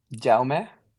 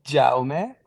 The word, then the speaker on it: Jaume: Jaume. I mean, in Spanish, then my name would be Jaime, but in my first language is Catalan, so it's pronounced Jaume. It's like a more quiet J.